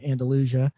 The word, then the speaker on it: Andalusia: And of course, Andalusia is the Spanish city, but we Southerners like to pronounce it differently. We have our own idioms and drawls and ways of saying things. So we say Andalusia.